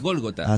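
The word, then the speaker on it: Gólgota: ¿Quién no es Golgota, es Golgota Rock. – Golgota. – Acento en la O.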